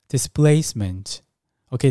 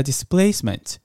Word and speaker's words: displacement: displacement，OK，、 okay, 听到这个 p 念作 b 的音，displacement 啊、哦，有 s 在前面，后面的 p 都会念成 b 的音。比如说 speak，s p e a k，speak 的 p 也是发 b 的音。那 displacement 也是一样。